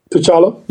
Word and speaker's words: T'Challa: T'Challa.